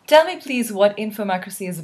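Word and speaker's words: Infomocracy: Joining me today on Midnight in Karachi is writer Malka Older, whose book, let me get that right, Infomacracy? Is that how you say it? – Perfect. – Infomacracy is now out and has been lauded all over the place.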